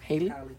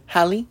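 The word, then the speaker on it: Halle: I'm gonna fucking DM Chloe and, how do you say her name? Haley? – Hallie.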